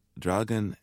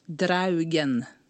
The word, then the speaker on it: Draugen: You call it the Draugen and the Keg. – Hmm. – How do I say it? Not dragon, because the English word is dragon, but this word is? – Draugen.